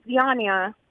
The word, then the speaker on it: Zyanya: Her name's Zyanya. – How do you spell that? – It's Z-Y-A-N-Y-A.